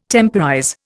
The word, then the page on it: temporize: Indian